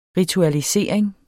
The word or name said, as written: ritualisering